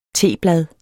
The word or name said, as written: teblad